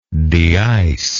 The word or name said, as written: deicing